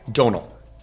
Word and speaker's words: Domhnall: Donal. – Donal? – Donal. – God, I'm going to say that. You don't pronounce the M. It's Donal.